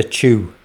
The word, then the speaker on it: Chua: The next track we're going to play is from Lucinda Chua. I think that's how you pronounce it. C-H-U-A. Chua. Chu. The album is on the 4AD label. It's called Yian. That's Y-I-A-N.